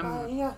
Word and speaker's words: Maia: She was a, Maia.